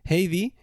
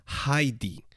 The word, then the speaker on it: Heidi: Heidi es la, la pronunciación española de este personaje. En alemán, el diptongo EI se pronuncia como AI. Es decir, que si lo pronunciásemos correctamente en alemán, tendríamos que decir siempre Heidi.